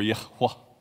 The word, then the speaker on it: Yahweh: Now, it's an interesting point if you think about the fact that the Hebrew people knew the name of God, Yud-Heh-Vav-Heh in Hebrew, or Yahweh is how it would be pronounced.